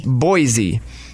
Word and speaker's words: Boise: Boise. Most people say with a Z, but it's actually Boise with an S.